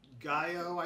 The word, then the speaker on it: Gyo: Um, it, it, was written by Junji Ito, who also did Gaio, I think is how it's pronounced, G Y O.